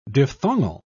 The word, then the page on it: diphthongal: British English